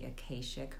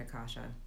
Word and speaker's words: Akashic: And I should say that you might hear it referred to as the Akashic Records, but I do pronounce it Akasha.